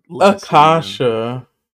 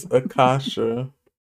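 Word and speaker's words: Akashia: It's like putting Acacia as the leader in... – Akasha. – You think she's earned the right for me to say her name correctly? – Yes, everyone has. Okay, it's Akasha.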